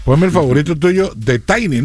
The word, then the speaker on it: Tini: Es Tiny. – Ajá, es Tiny. Ajá. – ¿Es Tiny? – Sí, pero le dicen Tini también. Ajá. Pero canta demasiado bien. – ¿Tiny? – Tini, se escribe Tini.